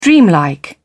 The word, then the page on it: dreamlike: British English